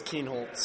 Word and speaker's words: Keenholz: I've heard crazy pronunciations before, and I feel like a lot of them is Keenholz. That's probably the main one I get, but it is Keenholz.